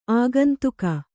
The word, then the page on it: ಆಗಂತುಕ: āgantuka